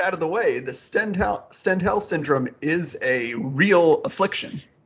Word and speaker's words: Stendhal: Stendhal is how the guy on the commentary pronounces it.